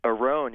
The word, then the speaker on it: Oron: My name's Aron. I'm calling from Philadelphia. – Aron? – Aron, yep. – How do you spell that? – A-R-O-N? – O-R-O-N. – O-R-O-N? – Yep.